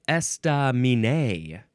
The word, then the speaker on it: estaminet: Estaminé. Now, is that... It looks like those first three syllables, none of them get emphasized more than the others. Estaminé. It is spelled E-S-T-A-M-I-N-E-T. Don't forget about the T.